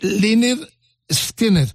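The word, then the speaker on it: Lynyrd Skynyrd: Eh, pronúnciese eh, Lynyrd Skinner sabéis que fue el primer disco de la banda que decía así, pronunciese bien, y, esta es la controversia muchas veces.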